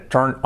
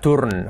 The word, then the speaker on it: turn: Sobre todo la pronunciación, que no sea turn, ¿ok? Turn, no, turn, turn, ese sonido.